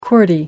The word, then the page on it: qwerty: American English